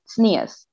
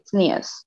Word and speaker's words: tznius: You know, for example, like modesty in Hebrew, but Hebrew with the accent of people who are from Eastern Europe, it's pronounced tznius.